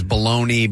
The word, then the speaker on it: bologna: It's spelled sometimes baloney, B-A-L-O-N-E-Y, since that is how many people pronounce it. However, its more common spelling is B-O-L-O-G-N-A. And they L-O-G-N-A.